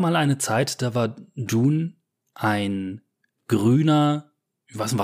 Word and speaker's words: Dune: Also Dune ist tatsächlich die richtige Aussprache.